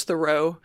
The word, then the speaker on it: The Row: which I had to play a video to make sure it's not pronounced The Row, because...